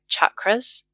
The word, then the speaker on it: chakras: And I just want to be able to slip in here that the pronunciation chakras is a westernized version, and it's a Sanskrit word with a hard ch, so chakras.